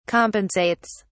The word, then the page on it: compensates: British English